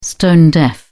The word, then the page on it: stone-deaf: British English